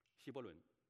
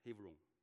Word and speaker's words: Hebron: 所以如果这个求，这个问耶和华这句话真的可以，这个问可以问成求告，或者说恳求，求问，哀求，甚至可以说希伯伦，希伯来文叫，不是这么发音的，希伯来文是 Hebron， Hebron。